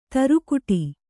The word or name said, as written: ತುರುಕುಡಿ